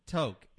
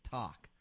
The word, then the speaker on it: Tok: We got a town up in Alaska called Tok, and it's spelled okay. T-O-K, and everybody tries to pronounce it Talk.